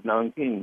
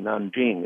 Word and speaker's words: Nanjing: In Nanking, the city of Nanking, Nanjing, it's pronounced today,